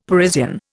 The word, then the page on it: Parisian: Indian